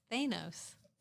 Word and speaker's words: Thanos: Thanos. Thanos? Thanos. Thanos. Okay, again, not a superhero girl. So, so you've got Hannibal Lecter, Lex Luthor, and Thanos.